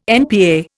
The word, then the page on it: NPA: Indian